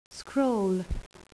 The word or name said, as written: scroll